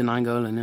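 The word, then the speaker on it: Nainggolan: I always got his pronunciation wrong. Nijngolan, is it? – Roger Nijngolan, yeah.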